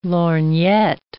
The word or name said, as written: lorgnette